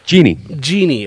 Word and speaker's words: jinni: I mean, shoved in with the devils and Ginny. Or how is that actually pronounced? J-I-N-N-I. – Genie.